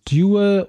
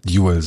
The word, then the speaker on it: Duel: Wie wollen wir einen Film aussprechen? Duel oder Duell? – Lass uns Duel sagen. – Duel, okay, Duel. Gut. Dann sagen wir ab jetzt Duel und ich verspreche jetzt schon, dass ich ein paar Mal noch Duell sagen werde.